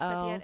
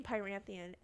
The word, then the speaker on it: Prythian: I heard it from somebody else on a YouTube channel and they're like Prithian and I was like, that makes more sense. – Yeah. No, you're right. That's how they say it in the audiobook. – Maybe I was saying Pyranthian and that wasn't right either.